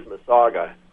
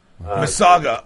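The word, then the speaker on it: Massasauga: Well, the pronunciation of your snake is Mississauga. – Mississauga. Okay, got it.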